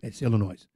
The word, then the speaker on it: Illinois: It's Illinois. – No, when did it become noise? I thought it was just noise. – You don't pronounce the S.